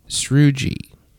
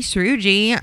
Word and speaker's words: Srouji: Jackie Srugi. Yes. I'm already a fan. – Her name is spelled J-A-C-Q-U-E. – Sure. – And sure. – Srugi is spelled S-R-O-U-J-I. – The J threw me.